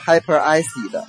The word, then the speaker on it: hyperacid: OK, 再往下 Hyperacid, Hyperacid, H-Y-P-E-R-A-C-I-D, 这里面我想提醒一下这个发音啊，它是由 Hyper 加上 Acid A-C-I-D, 但是不读作 Hyperacid, 它连在一起了，叫做 Hyperacid。